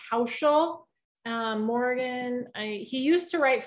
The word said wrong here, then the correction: Housel: I think it's Morgan Housel is how you pronounce it. Housel.